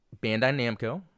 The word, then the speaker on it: Bandai Namco: Um, and I, I had a video released on it, and, you know, fortunate Bandai Namco.